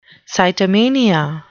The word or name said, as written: sitomania